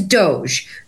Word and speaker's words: Doje: So my stage name today is Doge, but it is spelled D-O-J-E. – Okay, it's not spelled like Elon Musk's whatever it is, D-O-G-E, right? – Right. It is not. It's pronounced the same. It is spelled differently because my D-O-J-E stands for the Department of Jhana Efficiency.